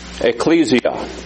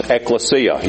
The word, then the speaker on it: ecclesia: And that word is ecclesia, also pronounced ecclesia. You may have heard it pronounced both ways. It is pronounced both ways. I think probably the more common use nowadays is ecclesia, but you still hear people saying ecclesia.